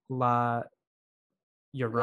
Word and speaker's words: La Llorona: Oh, I know the double L is pronounced like a Y, so here we go. La Llorona.